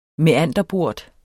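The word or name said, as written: MÆANDERBORTER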